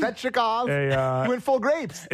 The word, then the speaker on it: Svechnikov: You know, Nekas, Shvechnikov. Is that how you say it? – Shvechnikov.